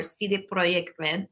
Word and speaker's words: CD Projekt Red: This year, we're actually in conjunction with CD Projekt Red, or CD Projekt Red, as they say.